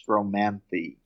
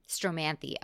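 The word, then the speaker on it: Stromanthe: So the Maranta, the Calathea, and the Stromanth, do they all have, first off, am I pronouncing those names correctly? – 2 out of 3. My understanding is that the last one is Stromanthi. – Stromanthi, okay.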